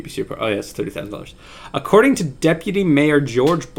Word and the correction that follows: Jorge: Blass, George spelled with J O R G E. – It's Jorge.